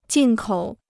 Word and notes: 进口: jìn kǒu

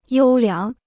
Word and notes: 优良: yōu liáng

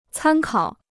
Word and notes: 参考: cān kǎo

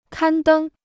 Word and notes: 刊登: kān dēng

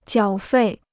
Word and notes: 缴费: jiǎo fèi

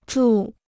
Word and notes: 住: zhù

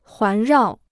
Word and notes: 环绕: huán rào

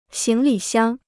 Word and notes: 行李箱: xíng li xiāng

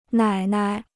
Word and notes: 奶奶: nǎi nai

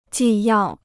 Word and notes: 纪要: jì yào

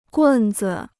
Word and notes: 棍子: gùn zi

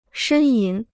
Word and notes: 呻吟: shēn yín

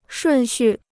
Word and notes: 顺序: shùn xù

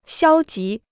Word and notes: 消极: xiāo jí

